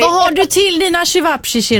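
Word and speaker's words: ćevapčići: Ja, men jag har, nu vet inte jag hur man uttalar, men selviche, köttfärskorvar, typ-ish. – Mm. Okej. – Menar du salsiccia? – Det är inte chivapchichi då? Chivapchichi. – Ja, så, precis.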